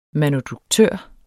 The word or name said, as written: manuduktør